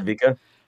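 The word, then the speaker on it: Sevika: What did you think of Savika? Is that how you say it? It's right, Savika? – Yeah, Savika.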